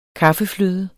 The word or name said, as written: kaffefløde